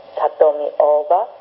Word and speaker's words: Satomi Oda: Satomi Oda. – Can you say her name once again? – Satomi, S-A-T-O-M-I, mm-hmm. – Oda, or the long O-O-O-O-B-A, Satomi Oda.